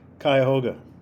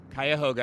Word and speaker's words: Cuyahoga: Cuyahoga. – Cuyahoga.